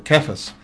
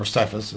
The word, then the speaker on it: Cephas: And, of course, the Hebrew for that was Kephas, or Cephas, as sometimes they pronounce it, Kephas, which means rock, of course.